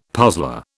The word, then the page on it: puzzler: British English